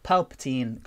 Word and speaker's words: Palpatine: And like, yeah, I mean, in theory, like Palpatine coming back, I did it.